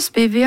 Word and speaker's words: Spivey: Det Spivey, ja, og jeg var jo på en lengre researchtur I USA og intervjuet folk, Og da ble det uttalt Spivey. Ja, så Bruce Ziegler I Alligator Records, for eksempel, sier Spivey, Spivey Records. Ja. På Bluesarkivet I Mississippi sa de også Spivey.